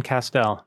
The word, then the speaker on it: Castel: Correct, Alan Castel. – Oh, good my God. Here. I can't believe I got it wrong. Honestly, I'm leaving. – Well, it rhymes with pastel. That's how you remember, right? – Damn it.